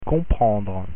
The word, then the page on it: comprendre: French